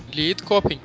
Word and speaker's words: Lidköping: A banda é de Lidköping, na Suécia. – Lidköping. – Lidköping, isso aí. – Lidköping, que bonita pronúncia, sueca.